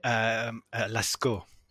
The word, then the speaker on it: Lascaux: Is it Lachaux? Is that how you say it? – Lascaux.